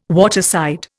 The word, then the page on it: waterside: Indian